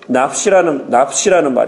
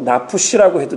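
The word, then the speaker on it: 납시라는 (נפש): 납시라고 해도 되고요. 납시라고도 납푸라는 그 말에 조금 여운을 넣으시고 발음하시면 됩니다. 제가 히브리가 잘 오래간만에 했더니 잘 안 되는데 납시라는 말로도 얘기해도 되고 납푸시라고 해도 돼요. 하지만 그 푸가 너무 길어지면 안 됩니다.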